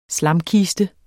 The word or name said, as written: slamkiste